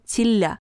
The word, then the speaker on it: Тилля: Сегодняшний жених носил фамилию Тилля. Надежда Степановна заранее уточнила, что ударение падает на второй слог.